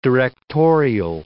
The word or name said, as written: directorial